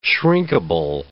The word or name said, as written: shrinkable